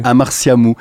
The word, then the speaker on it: Amartya Mou: Amartya Mou, c'est comme ça qu'on prononce ? – Oui, tout à fait. – Avec le T qui fait un S ou un T. – Exactement, Amartya Mou.